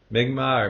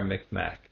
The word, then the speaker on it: Mi'kmaq: Mi'kmaq or Mi'kmaq. It's pronounced both ways.